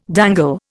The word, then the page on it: dangle: Indian